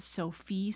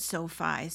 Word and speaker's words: SoFi: I've heard people pronounce it Sophie Stadium, but I also watched a video of the gentleman who built it. Well, not built it, but designed it. And he pronounced it SoFi, so I'm going to go with his pronunciation.